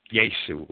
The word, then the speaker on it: Jesu: Jesu would be the proper German pronunciation of J-E-S-U, signifying 316.